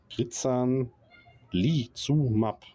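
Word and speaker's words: Crizanlizumab: Krizanlizumab. Ne, das habe ich schon richtig ausgesprochen.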